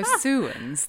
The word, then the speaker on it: Sowans: Yeah, so Sowans, thank you. – Sowans is actually spelled S O W A N S, which from where I come from in the southeast of England originally, I would say Soans.